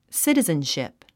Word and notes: citizenship: sítizənʃìp; スィティズンシップ

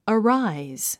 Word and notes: arise: əráiz; アラァイズ

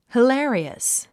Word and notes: hilarious: hilé ə riəs; ヒラリアス

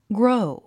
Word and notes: grow: gróu; グロォウ

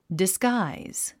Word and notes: disguise: disɡǽiz; ディスガイズ

